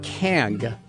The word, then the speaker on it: King: Russ is in Kang, or King, North Carolina. King, pronounced Kang, as a native.